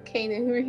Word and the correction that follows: Keanu: Keanu Reeves.